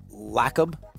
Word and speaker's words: Lacob: I had made a promise to myself that I would sleep reveals Lacob. Lacob. Lacob. Uh, which rhymes with Jacob, yeah.